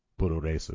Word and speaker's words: Puroresu: That is the katakana way to pronounce pro wrestling in Japan. So when they say Nihon Puroresu, it means Japanese pro wrestling.